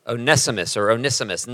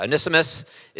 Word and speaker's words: Onesimus: Onesimus. Nobody knows how to say it, but yeah, Onesimus. It's about a guy named Onesimus who is a slave, but he's a runaway slave.